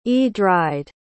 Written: edryd

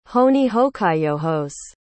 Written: hohnihohkaiyohos

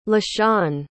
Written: lashawn

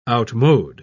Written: outmode

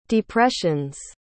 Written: depressions

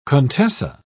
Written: contessa

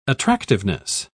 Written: attractiveness

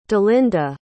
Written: delinda

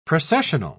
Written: processional

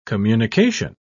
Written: communication

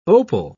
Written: opal

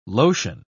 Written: lotion

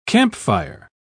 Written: campfire

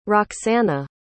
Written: roxana

